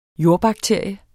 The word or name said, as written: jordbakterie